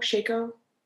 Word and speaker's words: shako: and I don't know if I'm saying that right, S-H-A-K-O, Shako? Shako? Shako? Write that down. – That seems right. – Does it? – Shako. Shako, yeah.